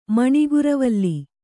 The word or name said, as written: ಮಣಿಗುರವಲ್ಲಿ